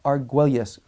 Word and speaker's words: Arguelles: His last name is spelled A-R-G-U-E-L-L-E-S, Arguelles. We would pronounce it Arguelles. But Archbishop Raymond Arguelles, you can address it to Lipa, L-I-P-A, comma, Philippines.